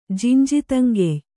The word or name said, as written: ಜಿಂಜಿತಂಗೆಯ್